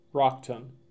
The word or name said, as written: Rockton